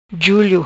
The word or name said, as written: Julio